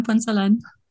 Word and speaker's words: Punzalan: Here with Pam Punzalan.